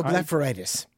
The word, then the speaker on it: blepharitis: Kathy emailed to say her mother has blepharitis, B-L-E-P-H-A-R-I. – Oh, blepharitis.